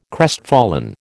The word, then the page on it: crestfallen: American English